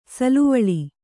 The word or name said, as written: ಸಾಲುವಳಿ